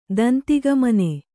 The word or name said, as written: ದಂತಿಗಮನೆ